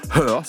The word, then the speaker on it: H.E.R.: Voici une voix que vous avez peut-être déjà eu l'occasion d'entendre, la chanteuse californienne Her, ça s'écrit H-E-R, voilà comme le pronom, un talent, remarquablement précoce, puisqu'elle avait tout juste 20 ans en 2017, quand elle a publié son tout premier album.